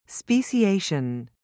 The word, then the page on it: speciation: American English